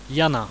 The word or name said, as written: jana